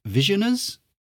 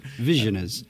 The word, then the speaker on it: Visioners: Anyway, so this is called Visioners. – Yeah. – Are we happy with that pronunciation? – Yeah. – Visioners.